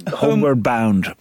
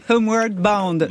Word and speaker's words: Homeward Bound: Vous voulez nous le prononcer euh, oh là là. Homeward Bound. – Homeward Bound.